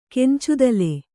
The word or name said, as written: ಕೆಂಚದಲೆ